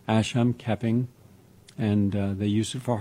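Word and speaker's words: Asam Keping: and it talks about the Asham Keping, let me spell that, A-S-A-M, Asham Keping, K-E-P-I-N-G.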